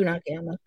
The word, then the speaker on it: Jun Akiyama: And it also had Eddie Kingston versus, is it Jun Akiyama? Mm-hmm. Is that how you say it? – Jun Akiyama.